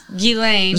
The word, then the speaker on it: Ghislaine: So Ghislaine is like G-I-H. – It's G-H-I-S-L-A-N-E. – G-H-I. – I'm not good at spelling. – Well, it's like French. Oh, yes. Ghislaine.